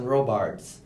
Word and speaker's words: Robard: But a lot of times when I say my name is Robard, it's the same as Jason Robards.